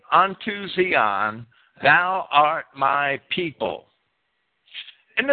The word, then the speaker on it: Zion: I know a lot of people want me to pronounce that Zion. Technically, it should be Zion.